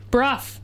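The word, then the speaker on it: Brugh: Made by Jesse Griffin, Jackie Van Beek, and Johnny Burr or Brug. I don't know. It's B-R-U-G-H. I'm not sure how to say it. – Bruff. Thanks.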